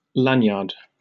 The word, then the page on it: lanyard: British English